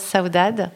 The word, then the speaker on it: saudade: Alors, avec le petit accent portugais, effectivement, qui n'est pas parfait non plus, mais ouais, on prononce Saudade.